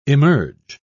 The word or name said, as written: emerge